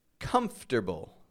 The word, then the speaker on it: comfortable: C-O-M-F-O-R-T-A-B-L-E. However, I pronounce it as if it's comfter, comfter. I take the F, and then I go straight to the T, and then I rearrange the letters.